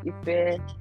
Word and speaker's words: Ife: But yeah, just Ife. Think of effect.